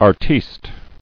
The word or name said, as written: Artiste